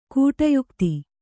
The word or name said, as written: ಕೂಟಯುಕ್ತಿ